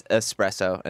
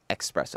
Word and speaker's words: espresso: There are some people reminding you that it's espresso and not espresso.